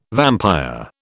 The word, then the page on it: vampire: British English